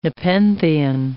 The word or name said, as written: nepenthean